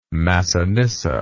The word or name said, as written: Masinissa